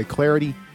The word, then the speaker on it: Clardy: You can follow me at Troy Clarity. Last name is spelled C-L-A-R-D-Y.